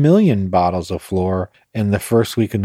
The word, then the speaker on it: Shloer: And it's funny, it's spelled S-H-L-O-E-R, but it's pronounced floor.